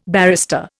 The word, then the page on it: barrister: Indian